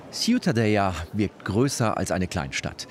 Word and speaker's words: Ciutadella: Aber Ciutadella, richtig ausgesprochen? – Ja, das Städtchen, Ciutadella.